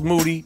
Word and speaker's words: Moody: Gerald Moody, G. Moody, a.k.a. the Black Ed McMahon. Moody rhymes with duty.